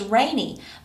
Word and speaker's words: Raeni: And her name is Rainey, but it's spelled R-A-E-N-I.